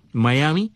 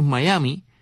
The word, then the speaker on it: Miami: Pero cuando llegas allí y dices Miami, la gente te mira con cara rara, dice, este tipo que está diciendo, no Miami, y es que es Miami. Mira tú qué cosa loca, no es Miami, Miami. O sea, la M-I final la pronuncian como Mai.